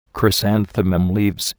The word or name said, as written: chrysanthemum leaves